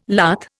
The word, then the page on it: lath: Indian